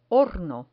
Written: horno

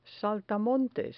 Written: saltamontes

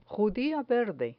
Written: judia verde

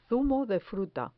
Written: zumo de fruta

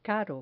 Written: caro

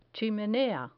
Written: chimenea

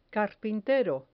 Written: carpintero

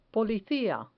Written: policia

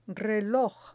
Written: reloj

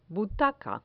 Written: butaca

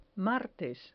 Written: martes

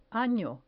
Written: ano

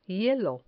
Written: hielo

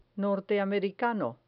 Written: norteamericano

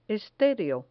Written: estereo

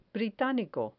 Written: britanico

